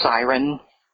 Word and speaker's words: Cyryn: Her name, and I'm not making this up, was Siren, spelled C-Y-R-Y-N, but pronounced Siren.